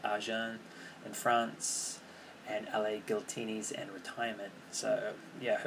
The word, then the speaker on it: Giltinis: I've heard that Giltinis rhymes with Martini or Martinis, so it's Giltinis.